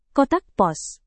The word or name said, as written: kotak pos